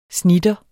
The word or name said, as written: snitter